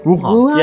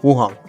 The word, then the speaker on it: Wuhan: The city, I don't know how to say it. It's Wuhan. – Wuhan. – Yeah, Wuhan.